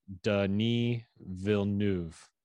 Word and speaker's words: Denis Villeneuve: This is how you say it. Denis Villeneuve.